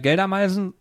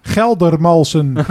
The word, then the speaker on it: Geldermalsen: Weil wir waren, also man muss das so erklären, wir waren eingeladen von Napoleon in Geldermeisen. Ist richtig ausgesprochen? – Geldermeisen, ja. Ja. – In Holland. Und soll ich das in Holland sagen? – Ja, genau. – Geldermalsen.